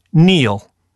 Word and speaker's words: kneel: Finally, raspberry, answer, Neil. – How are you spelling Neil? – K-N-E-E-L, Neil.